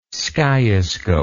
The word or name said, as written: skiascope